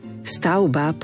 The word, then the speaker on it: stavba: Dajme si pozor, slovo stavba povieme vždy v strede, to V vyslovíme ako U, podobne ovca, pravda, krivda.